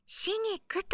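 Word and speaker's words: 紫菊: 何せここは秘境の隠れ家、足湯カフェシギクですから。心を落ち着けるには最適な環境です。はい、それでは奥へご案内いたしますね。 – え？シギクですか？紫の菊と書いて、シギクと読みます。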